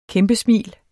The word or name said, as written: kæmpesmil